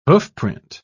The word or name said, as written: hoofprint